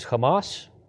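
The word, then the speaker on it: Hamas (חמס): Violence is Hamas. That's the Hebrew word. That's how you pronounce violence in Hebrew.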